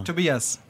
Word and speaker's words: Tobias: Tobias. – And your full name? – Like two beers. – Two beers and what? Beers. What's your last name? – Holler, H-O-L-L-E-R.